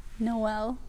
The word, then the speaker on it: Noel: Noel. – Noel. I wasn't sure if it was Noel or if it was just Noel. Cool. – Mm-hmm. Like Christmas.